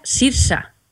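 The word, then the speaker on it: Saoirse: Se pronuncia Sirsa.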